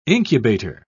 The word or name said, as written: incubator